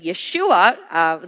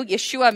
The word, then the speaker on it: Yeshua: Now you're going to really hear the name Yeshua. Those of you who are new, Yeshua means the Savior, and it is the original name, original pronunciation for the English name Jesus.